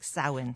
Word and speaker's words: Samhain: Well, so they call it Samhain, is it? S-A-M-H-A-N. – Yeah, that's the original Celtic name for this time of year. It's pronounced like Samhain.